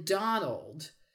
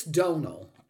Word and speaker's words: Donal: But they say, oddly enough, that the D in Donald he says it's Donal. Okay, but the final D in Donald, I found out, was actually partly because Gaelic pronunciation misinterpreted when it was translated into English. – Into English. – So Donald is technically the wrong pronunciation of Donal.